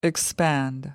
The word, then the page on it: expand: American English